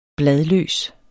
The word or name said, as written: bladløs